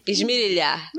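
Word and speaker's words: esmerilhar: Aliás, a gente precisa mais esmilir. Como é que fala? – esmilhar Esmirilhar. Esmirilhar. Esmirilhar.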